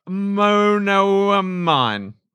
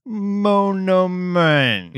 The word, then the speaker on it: monoamine: Is required for the production of sleep monoamine. How do I say that? You'd know. – What I mean. – Yeah, absolutely. – It's pronounced monoamine.